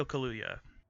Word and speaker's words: Kaluuya: It's Daniel Kaluuya. I don't know if I'm pronouncing that right. He plays Chris Washington. Excuse me. – Yes, Daniel Kaluuya.